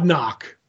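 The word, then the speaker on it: Nauck: written by Jeff Johns with art by Todd Nanock. Is that how you pronounce it? – I believe it's just Todd Nock. – Todd Nock, okay.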